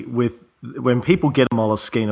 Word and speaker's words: Moleskine: I say it wrong, Moleskine. It's Moleskine.